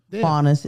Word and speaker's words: Faunus: Faunus is fine.